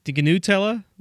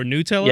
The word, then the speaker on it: Gnutella: Wasn't it pronounced Gnutella or Nutella? – Yes. Thank you. – It depends on how you like to pronounce the G in GNU products.